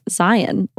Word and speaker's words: Zion: So we went to Bryce Canyon, Zion National Park, which actually is pronounced Zion, like lion.